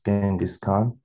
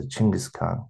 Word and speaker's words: Chinggis Khan: First of all, like in the different countries, it's like our king is pronounced as Genghis Khan. But from our side, we would like to like tell people that it's not Genghis Khan. It's pronounced as a Chinggis Khan. Because like, if you guys go like see like the good sides he did, and also like pronounce his name as like in a correct way, which is like Chinggis Khan, that would be like really like.